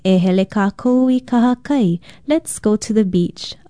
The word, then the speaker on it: kahakai: Aloha, I'm Leilani Poliahu of Hawaii Public Radio, and our Hawaiian word for today is kahakai. Kahakai is spelled K-A-H-A-K-A-I, and it means beach, seashore, sea coast, seaside, or strand.